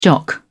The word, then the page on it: jock: British English